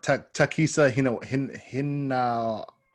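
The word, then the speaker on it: Takehisa Hinawa: Takisa Hinawa. – I mean, that sounds better than what I said.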